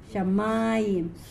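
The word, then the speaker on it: Shamayim (שמים): Okay, you see this here? That means that is where the accent is. Shamayim. So this is penultimate. Okay? Penultimate because this is not the end. This is not the syllable at the end.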